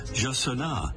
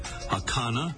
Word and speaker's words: jacana: About those pronunciations, the Brazilian Portuguese way is Jassana. Spanish versions include Hakana and Hassana and Hassana.